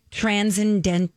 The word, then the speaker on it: transcendent: So it's, she says, the voyages of Black families throughout time are honored in this tale about a young king's transcendent, how do you say this word? – Transcendental. – It doesn't say the dull part. – Transcendent. – Thank you.